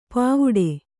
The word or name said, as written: ಪಾವುಡೆ